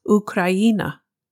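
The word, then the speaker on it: Ukraina (Україна): Official name, Ukraine, or as they say in Ukraine, Ukraina.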